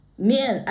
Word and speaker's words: mian: It was called chow mein. – Mian. I can recognize the last one. It's mian.